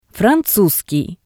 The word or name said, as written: franzuskii